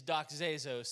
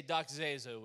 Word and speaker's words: doxazo: Now, if you look that word glorify up in the Greek, That word is doxazo. Say doxazo with me.